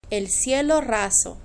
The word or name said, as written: el cielo raso